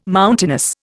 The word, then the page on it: mountainous: Indian